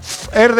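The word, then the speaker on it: Fher: Fer con H, ¿no? – Fer. Así se pronuncia, Fer.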